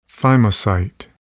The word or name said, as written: thymocyte